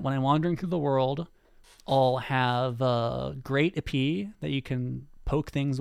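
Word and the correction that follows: épée: I may have also mispronounced EP. I'm sorry. I'm terrible. Epée. I'm bad at pronouncing French stuff.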